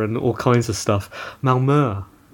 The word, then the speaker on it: Malmö: So I currently live in the lovely south of Sweden in a town called Malmö.